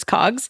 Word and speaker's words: COGS: So this is often called the cost of goods sold, sometimes abbreviated as COGS, and pronounced as COGS.